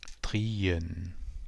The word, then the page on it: drien: Kordel